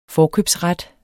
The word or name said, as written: forkøbsret